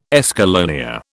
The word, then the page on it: escallonia: British English